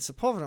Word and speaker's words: Sepulveda: And then somebody said Sepulveda. I'm like, oh, that's how you say it.